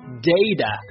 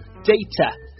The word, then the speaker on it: data: 这个词呢拼写是 D-A-T-A data，它第一个元音呢是 A day，第二个这个辅音呢是 t，但是呢如果是美音呢一般会浊化成 d，一般是读成 data 而不是 data， data 呢就是英式的发音，注意一下英式发音 t 不浊化， data，美式发音 t 浊化， data，这个词呢就可以表示手机流量的意思，它最常用的意思呢是数据，比方说数据库呢就叫做 database 就是数据库，但是呢一旦用到手机方面就是手机流量的意思。